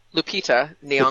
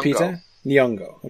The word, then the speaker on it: Lupita Nyong'o: Lupita Nyong'o. – Lupita Nyong'o. Okay, Lupita Nyong'o.